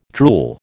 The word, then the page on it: drool: American English